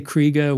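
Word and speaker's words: Krige: You know, at a very young age, Alice, is it Krieg or Kriega? I've heard it pronounced two different ways. – I say Kriega, which would be technically how it is, but I think most people say Krieg.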